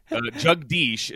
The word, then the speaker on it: Jagdish: So Jugdish is actually probably how it's more accurately pronounced in India, but that means ruler of the world.